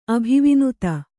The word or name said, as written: ಅಭಿವಿನುತ